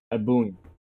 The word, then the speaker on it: ebullient: Can you please post a video of someone pronouncing it? Ebullient. Please. So you can understand how to properly pronounce it, you uncultured swine.